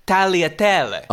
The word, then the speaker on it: tagliatelle: You don't even pronounce the G. Apparently when a G is next to an L in Italian, you don't pronounce the G. So it's tagliatelle.